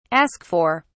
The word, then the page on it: ask for: British English